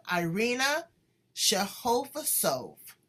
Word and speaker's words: Irina Shehovsov: And today we have Irina Shehovsov.